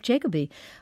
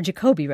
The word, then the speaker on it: Jacoby: But also others like conservative commentator Jeff Jacoby, who said – or Jacoby, rather. He's a good friend. Mm-hmm. I can't believe I mispronounced that.